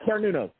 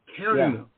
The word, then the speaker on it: Cernunnos: So you were familiar with, I'm going to try to pronounce his name again, Sununus, or Sununos, right? I'm probably saying that. Cernunnos, yeah. – Cernunnos.